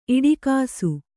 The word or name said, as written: ಇಡಿಗಿಸು